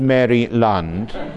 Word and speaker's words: Maryland: It's not two words, Maryland. Maryland.